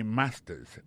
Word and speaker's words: master's: Accent tonique là, I got a master's. Master's là, c'est mot créé, les maîtrises là. Regardez bien pour voir que l'accent est tombé sur le premier syllabe là.